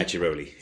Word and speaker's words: Acciaroli: Acciaroli? – Acciaroli.